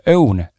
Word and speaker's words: Õun: ma arvan, ma ei eksi, kui ma ütlen sulle, et sa oled seda brändi äh, või sellest brändist varem kuulnud, brändi nimega Own päris keeruline Tallinnas, Tallinna poisil seda hääldada, ma arvan, et saarlastel tuleb see hääldamine parem välja, aga õun, Ö-U-N, mis on siis tõepoolest õuntest valmistatud mahedad energiat ja immuunsust toetavad käsitööjoogid.